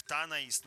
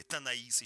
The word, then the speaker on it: Танаис: Тогда музей-заповедник Танаис, ну или Танаис еще можно встретить, вот такой вариант ударения, принял первых посетителей.